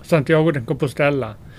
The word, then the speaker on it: Santiago de Compostela: Det här Santiago de Compostela eller? – Santiago de Compostela.